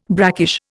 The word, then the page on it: brackish: Indian